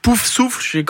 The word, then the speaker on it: Poufsouffle: Rien à voir avec moi sur le blason de l'univers d'Harry Potter sur la maison Poufsouffle. C'est comme ça qu'on le prononce, ouais c'est ça.